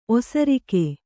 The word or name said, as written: ಓಸರಿಕೆ